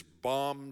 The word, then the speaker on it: Bom: It's in Portuguese, but the name of the church is Bom Jesus. Now, they pronounce it Bo Jesus.